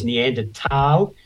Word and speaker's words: Neanderthal: apparently the correct pronunciation is Neanderthal. Tal actually means, it's a German word for valley. So Neander is the name of the valley where they were first found, and Tal is the German word for the valley, I believe.